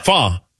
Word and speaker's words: pho: Fa. – Oh. – Yeah. P-H-O. – I thought you said three words. – three letters. I'm sorry. My bad. I might have said three words. three letters. Fa. And I go, how do you get fa out of P-H-O?